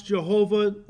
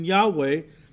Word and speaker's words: Yahweh: A sacred name, of course, known as the Tetragrammaton, four letters, which in English we pronounce Jehovah or most often Yahweh, but it comes from the Hebrew YHWH, the Tetragrammaton.